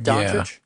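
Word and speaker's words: Dončić: Right now, the projected top two picks are DeAndre Ayton and Luka Doncic, I think I'm saying it right. Doncic? Yeah, yes.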